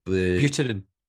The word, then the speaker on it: Buterin: Buterin. – Who is the co-founder of Ethereum. – Yeah. – Which is quite amazing that it's going that mainstream. – Yeah. So Vitalik Buterin is one of the co-founders.